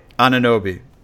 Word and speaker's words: Anunoby: Anunoby.